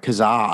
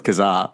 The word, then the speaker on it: Kazaa: Was it Kaza? Was I saying it wrong? – I said Kazaa. – Yeah, I thought it was Kazaa. – Who said Kaza? – Dude.